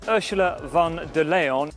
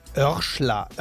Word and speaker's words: Ursula von der Leyen: Ursula von der Leyen. Ursula von der Leyen. Ursula von der Leyen. Örschler, Örsler, Leon, Lehn, Lein. Also Örschler finde ich aber schon hart.